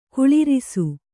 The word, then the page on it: ಕುಳ್ಳಿರಿಸು: kuḷḷirisu